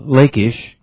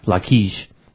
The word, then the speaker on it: Lachish: Kibbut Arias is located close to an important archaeological site where a center, Lachish, that's the way Thiem would pronounce it, I think Robbie Dean pronounces it Lachish, take your pick, often featured in the Bible, once stood.